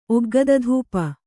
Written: ಒಗ್ಗದಧೂಪ

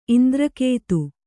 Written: ಇಂದ್ರಕೇತು

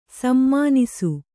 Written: ಸುಮನಸ್ಸು

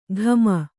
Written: ಘಮ

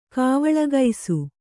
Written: ಕಾವಳಗೈಸು

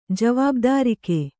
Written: ಜವಾಬ್ದಾರಿಕೆ

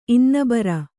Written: ಇನ್ನೆಬರ

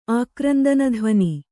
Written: ಆಕ್ರಂದನಧ್ವನಿ

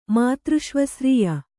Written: ಮಾತೃಷ್ವಸ್ರೀಯ

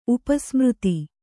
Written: ಉಪಸ್ಮೃತಿ

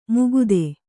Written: ಮುಕುತೆ

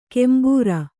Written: ಕೆಂಬೂರ

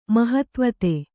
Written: ಮಹತ್ವತೆ

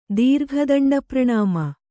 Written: ದೀರ್ಘದಂಡಪ್ರಣಾಮ

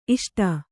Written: ಇಷ್ಟೆ

